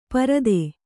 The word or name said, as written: ಪಾರುಧಿ